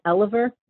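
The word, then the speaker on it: Elover: My last name is pronounced Eliver, but it's actually spelled E Lover.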